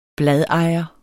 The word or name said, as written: bladejer